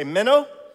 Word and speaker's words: meno: It's this word, meno. Here you say meno. This one's easy, yeah, to pronounce.